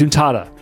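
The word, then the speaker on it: Zuntata: And we also have the one from Taito, pronounced Zuntada.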